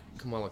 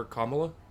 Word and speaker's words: Kamala: Which, by the way, I'm so irritated to all of you that call her Kamala. It is Kamala. – Right, Kamala. Right, right. Put the right emphasis on the right syllable.